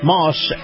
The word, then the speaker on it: Maus: Moss Acura. Moss is spelled M-A-U-S, and they do business all around the country.